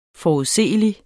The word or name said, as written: forudseelig